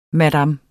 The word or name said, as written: madam